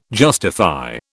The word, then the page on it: justify: British English